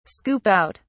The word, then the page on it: scoop out: British English